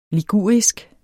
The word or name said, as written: ligurisk